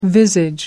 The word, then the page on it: visage: American English